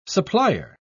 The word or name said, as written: supplier